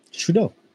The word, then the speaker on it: Trudeau: Trudeau. true no, DAO. True dough, like dough.